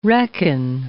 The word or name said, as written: reckon